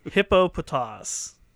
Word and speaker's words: Hippopotas: It is Hippopotas.